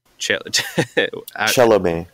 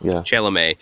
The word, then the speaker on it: Chelomey: But then the other two, Glushko and you have to help me on the pronunciation of Chelomey. – Chelomey.